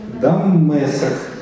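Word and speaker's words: Дамесок (דמשק): Дамесок. Дамесок. – Ударение на предпоследнем слоге, видите, там отмечено специально.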